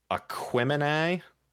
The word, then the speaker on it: Aquemini: Aquemini by Outkast. The reason that I pronounce that weird is in the little blurb about the album, it points out that it's a combination of the star signs.